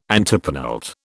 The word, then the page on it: antepenult: British English